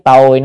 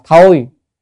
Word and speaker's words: tồi: Tồi, chữ này á, chữ tồi này cũng có nghĩa đọc có âm khác là thôi, nhưng mà trong miền Nam hay đọc là tồi.